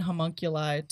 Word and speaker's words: homunculi: The stakes are always high, very sweet, innocent, homunculacal. How do you say that word? – Okay. The stakes are always high, from the sweet, innocent homunculi to otherworldly, angelic, ultra-terrestrials.